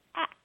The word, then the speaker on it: ask: Well, I have a question about a very short and simple little word, the word ask. I had begun to notice, I don't know when, but years ago, that there was an alternative pronunciation, and it was ax, like it was spelled A-K-S.